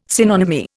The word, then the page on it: synonymy: Indian